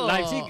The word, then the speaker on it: Leipzig: Leipzig es lo que nos dijeron que teníamos que decir.